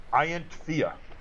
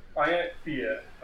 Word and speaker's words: Ianthea: Ianthea. It's Elvish. – Okay, can you spell that? – I can, yes, because I looked it up. – Okay. – The Silmarillion is a wonderful thing. I-A-N-T-F-E-A. – Ianthea.